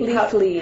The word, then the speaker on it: Leafly: It has like a little rating scale and people can make comments and could you say Leafly? – Leafly. – How would you spell that? – L-E-A-F-L-Y.